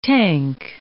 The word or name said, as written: tank